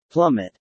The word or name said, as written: plummet